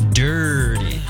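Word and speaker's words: Dirrty: And you actually did not pronounce it correctly. It's Dirty. – Wow, yeah, those extra R's. You're right. – She puts two R's in there, yeah.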